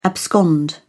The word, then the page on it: abscond: British English